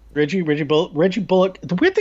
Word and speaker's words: Bullock: There was a whole point where he made it clear that his name is, in fact, pronounced Bullock.